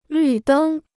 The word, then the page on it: 绿灯: lǜ dēng